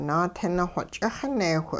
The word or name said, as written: naatinixwe ch'ixineewh